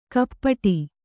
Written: ಕಪಾಟೆ

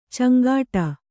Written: ಚಂಗಾಟ

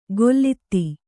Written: ಗೊಲ್ಲಿತಿ

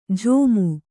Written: ಝೋಮು

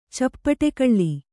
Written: ಚಪ್ಪಡಿಕಲ್ಲು

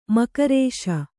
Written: ಮೂಗೇರಿಸು

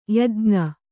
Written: ಯಾಜ್ಞ

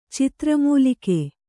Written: ಚಿತ್ರಮೂಲಿಕೆ

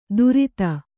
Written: ದೂರಿತ

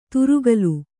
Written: ತುರುಗಲು

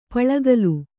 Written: ಪುಲ್ಗಲಿ

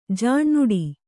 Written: ಜಾಣ್ನುಡಿ

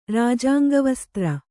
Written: ರಾಜಾಂಗವಸ್ತ್ರ

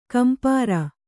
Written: ಕಂಪ್ರ

